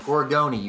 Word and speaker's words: Gorgone: And it's actually pronounced Gorgone. You pronounce the E at the end.